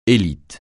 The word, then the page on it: élite: francais